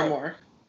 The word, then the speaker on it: armoire: And an armoire's door, I'm saying that wrong probably. – Armoire. – Thank you.